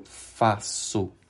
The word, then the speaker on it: faço: F-A-C cedilha, que é aquela letra C, com o sinal diacrítico embaixo da letra C, e tem o som da letra S, e a letra O, eu faço.